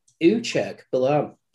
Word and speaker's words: Uchegbalam: I, I was pronouncing the G harder there. I think. – I think you were giving it a couple more extra syllables than what it required. It's uh, yeah, Uchegbalam. Uchegbalam.